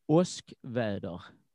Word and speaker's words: Oskväder: The first one out is a band called in in I have to say this in Swedish because it is in Swedish, and if you if I say it, it's pronounced like this, Oskväder, and it can be translated into thunderstorm, and so that says it all.